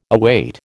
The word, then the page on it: await: American English